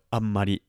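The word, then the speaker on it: amari (あまり): Amari is an adjective that's pronounced just like so, amari, amari. So make sure you're getting that sound in there very emphatically, if you will.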